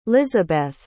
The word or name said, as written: lisabeth